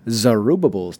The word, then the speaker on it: Zerubbabel: All right, so going into Second Temple, Zerubbabel's Temple. – Best name ever in a Bible. – Right? Sure. I am shocked I pronounced that right.